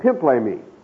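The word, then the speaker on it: pimplemi (πίμπλημι): The second word is pimplemi. Pimplemi. And that is spelled P-I-M-P-L-pimplemi. Pimplemi in Greek.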